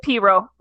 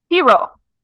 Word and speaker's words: pirogue: And it's a piro, by the way. Is the way you pronounce that word.